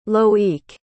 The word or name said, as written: loïc